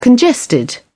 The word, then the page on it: congested: British English